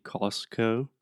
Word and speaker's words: Costco: So technically, it would be like cost, like the price, and then co, like company. – Company. – So I imagine the more correct way to say it would be Costco so hard, Costco.